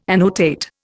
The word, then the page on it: annotate: Indian